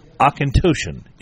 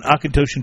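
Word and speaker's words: Auchentoshan: and I believe it is Akintoshin is the way you say it. It's an Akintoshin 12-year single malt.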